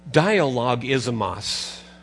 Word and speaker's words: Dialogismos: Here's the Greek word. Dialogismos. And I changed the pronunciation just a little bit so that you could capture the word. We have an English word, don't we? Dialogue. It says dialogism with the os at the end.